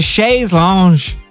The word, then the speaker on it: chaise lounge: Is it a chaise lounge? – Yeah.